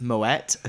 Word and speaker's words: Moët: Moe is pronounced Moët officially.